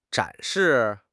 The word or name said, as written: 展示